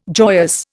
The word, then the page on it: joyous: Indian